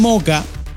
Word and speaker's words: Mocha: Se escribe Mocha. Pero el puerto de Moca, Moca con CH por si acaso, como Moca, ¿verdad? Como si fuera el café.